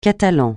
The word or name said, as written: catalan